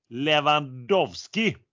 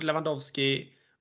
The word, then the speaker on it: Lewandowski: Lewandowski. Och den var också den helt klockren. Mario Gomez. Var det bra uttal tyckte du? – Alla tre var helt klockrena. David Alaba, Robert Lewandowski och Mario Gomez.